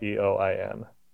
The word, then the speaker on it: Eoin: My first name is spelled Eoin.